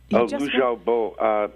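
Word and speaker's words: Liu Xiaobo: Lu Xiaobo.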